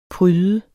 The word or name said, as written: pryde